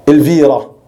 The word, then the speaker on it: Elvira: Ilbira, qui en espagnol se prononce Elvira. Ilbira, Elvira en espagnol, qui est une ville de Grenade, qu'on appelle en arabe Granada.